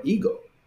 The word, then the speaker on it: ego: the word for I is ego, or ego. Like, that's how you say it.